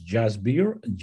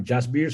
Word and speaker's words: Jasbir: So my official name is Jasbir, J-A-S-B-I-R, Jasbir Singh Kalsi.